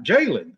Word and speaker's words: Jalen: Jalen, that's what I thought. I said it right.